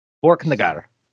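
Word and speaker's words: Borknagar: Have I been saying that correctly? Borkenegard. – That's what it, that's all good.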